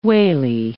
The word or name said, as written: wally